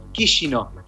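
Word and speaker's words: Chișinău: yo estaba en Kishino, que es la capital de Moldavia, que se escribe Chisinau, pero se pronuncia Chisinau.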